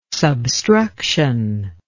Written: substruction

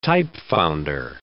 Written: typefounder

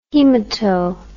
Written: haemato

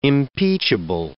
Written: impeachable

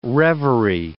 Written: revery

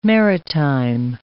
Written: maritime

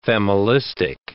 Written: familistic

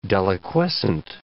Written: deliquescent

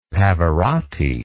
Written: Pavarotti

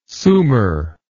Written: sumer

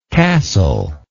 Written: castlelike

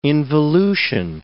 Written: involution

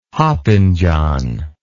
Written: hopping John

